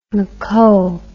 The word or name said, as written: moco